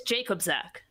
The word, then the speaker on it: Jakobczak: Mine is Jakobczak.